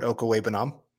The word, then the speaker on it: Okwuegbunam: Albert Okwuegbunam.